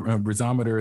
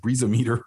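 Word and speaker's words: BreezoMeter: And I want to make sure I like the way you said your company's name, and you obviously know how to say it. So I'm going to go with Breeze-O-Meter as well, because I think I said Breeze-O-Meter earlier.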